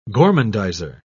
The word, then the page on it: gormandizer: British English